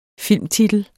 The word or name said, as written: filmtitel